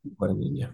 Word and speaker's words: Guadagnino: Um, when they announced the director, yeah, Luca um, Guadagnino, um, I think is his name. Yeah, Guadagnino.